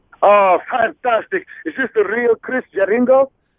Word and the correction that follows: Jericho: It's actually Jericho, but I know you're from Italy, and sometimes you have a problem pronouncing names, but Jericho.